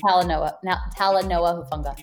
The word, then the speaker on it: Talanoa Hufanga: Talanoa Hufanga.